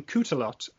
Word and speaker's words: Cootalot: And at N Cootalot, and that I will also spell, which is at N C O O T A L O T. Cootalot. Spell it like you would pronounce it. – Yes. It's it's spelled like it's pronounced.